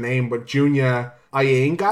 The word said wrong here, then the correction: Iyanaga: Iyanaga. – I think it's Iyanaga. Iyanaga, thank you.